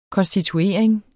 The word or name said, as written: konstituering